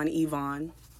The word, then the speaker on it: Yvonne: I believe that's how she pronounced her name, Yvonne. The Y, it's a French name, but the Y in the American alphabet is not pronounced E like it is in the French alphabet.